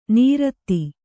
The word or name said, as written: ನೀರಧಿ